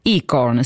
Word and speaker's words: ICORN: ICORN, si pronuncia così, giusto? – Sì, perfettamente.